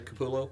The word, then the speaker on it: Capullo: Did I say that right? Capullo? – It's Capullo. That's how I say it.